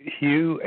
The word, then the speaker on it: Hugh: My name is Hugh, H-U-G-H. – Mm-hmm. – The last name, Trawlson, is spelled T like Tom, and R-A-U-L-S like Sam, E like Edward, N like Nancy.